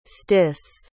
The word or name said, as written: stys